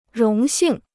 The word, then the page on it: 荣幸: róng xìng